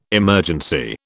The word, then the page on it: emergency: British English